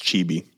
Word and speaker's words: Chibi: That's Chibi.